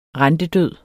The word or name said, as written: rentedød